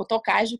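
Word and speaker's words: Tokaji: Outra bebida que também nunca deixo de mencionar para os meus clientes é o vinho dos reis, o rei dos vinhos, que o vinho Tokai. O Tokage, como muitas vezes é pronunciado, na região Nordeste, que existe 24 aldeias, é uma região que é produzida esse vinho.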